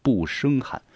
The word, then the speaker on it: 胜: 不胜寒，那个胜字呢是现在的胜利的胜，但是在古时要读一声，不胜寒，它代表的是一种呢古文化。